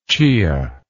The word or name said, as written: chia